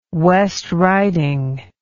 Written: West Riding

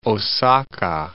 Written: Osaka

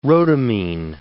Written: rhodamine

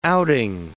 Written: outing

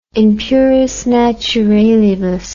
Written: in puris naturalibus

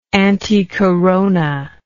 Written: anticorona

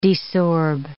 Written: desorb

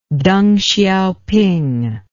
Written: teng hsiaoping

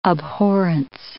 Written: abhorrence

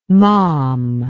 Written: malm

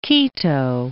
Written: Quito

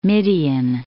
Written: Midian

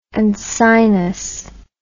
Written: uncinus

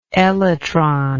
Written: elytrous

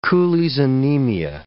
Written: Cooley's anemia

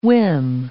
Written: whim

